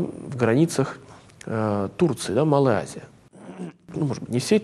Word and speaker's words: Азия: что город Эфес – это столица провинции Асия, ну, такое славянское произношение, на самом деле мы можем говорить по-русски Азия, да, провинция.